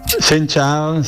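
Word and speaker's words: Xin chào: Chao, Buoi, Cheu. Se dice así, ¿no? – No. – ¿Que no? – Sen chao, sí.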